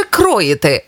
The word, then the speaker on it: кроїти: Кроїти чи кроїти? Як правильно? Кроїти. Ось так правильно. Наголос на О. Кроїти.